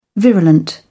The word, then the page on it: virulent: British English